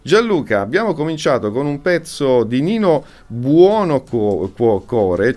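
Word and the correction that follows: Buonocore: cioè Buono Core. Io da ragazzo non riuscivo mai a pronunciare bene il suo cognome, dicevo sempre infatti, Buono Core.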